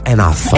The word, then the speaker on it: enough: Ah, ecco, Can't Get Enough, perché Enough si pronuncia Enough. – Enough, certo, ragazzi. Allora, lezione di inglese qui su Radio Marte, facciamola. Quando una parola, quando c'è GH, si legge sempre F, capito, enough.